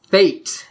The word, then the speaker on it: fete: The first word is fete, but it's spelled F-E-T-E, fate, and it's a festival.